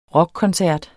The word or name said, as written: rockkoncert